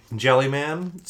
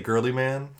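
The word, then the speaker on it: Guilliman: Some people say Jellyman, some people say Girlyman, some say Gilliman.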